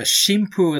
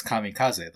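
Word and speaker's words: Shinpu (神風): And the Shinpu at the beginning, if you change the pronunciation, can be read as kamikaze, the way you read the kanji.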